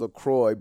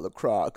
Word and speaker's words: LaCroix: I know it's LaCroix, guys. You told me over and over again. I think I got like 8 DMs. Yo, I don't want to be that guy, but it's LaCroix, bro, not LaCroix.